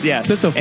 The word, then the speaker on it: Sisyphus: Sisyphus.